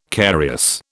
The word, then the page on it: carious: British English